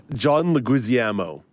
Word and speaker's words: Leguizamo: How do you say that again? – John Leguizamo. Leguizamo? – Yes. – Sure. Close enough.